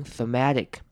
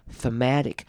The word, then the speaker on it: thematic: In terms of emotional and thematic content，这边 thematic 的 the 呢，则是要念成轻音 thematic， thematic 重音在 ma 这个音节上面 thematic， thematic。